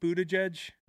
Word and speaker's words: Buttigieg: Brad Stevens kind of looks like Pete Buttigieg. – That's right. Good pronunciation.